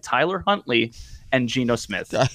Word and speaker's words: Tyler Huntley: Because just the name Tyler Huntley makes me want to just break things at this point. I'm traumatized after last week. – Hey, you said it right. You said it right this time.